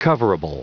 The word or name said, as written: coverable